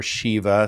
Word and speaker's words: Shiva: And Shiva or Shiva, some people pronounce it, would be the destroyer.